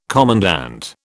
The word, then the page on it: commandant: British English